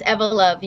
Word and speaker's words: Evolove: You guys, Evolove, how do you pronounce it? Is it Evolove or Evolove? – Yes, it's Evolove. You have it correct.